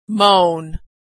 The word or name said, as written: mown